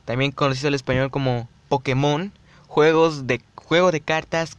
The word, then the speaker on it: Pokémon: Su pronunciación correcta es Pokémon.